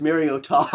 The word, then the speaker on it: Mariota: It's Ta as in Utah. Mormon motherfuckers. Okay, so we've settled it. Ta's in Utah. – You think it's pronounced Utah? – Yeah, the state? – Utah. So it's Mariota.